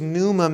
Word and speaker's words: pneuma: It's spelled P-N-E-U-M-A, pneuma.